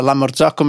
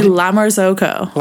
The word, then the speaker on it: La Marzocco: We say La Marzocco. Like, it's not like La Marzocco. It's just Marzocco.